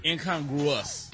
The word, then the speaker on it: incongruous: Incongruence? – Close. Wow. – Wait a minute. – Incongruence. – Okay. – What? – That's not it? – It was closer the first time. – Incongruous. – That was supposed to be the word. – Well, you messed it up. I knew I was saying it right. – The writer messed it up. – They messed it up. – It's incongruous.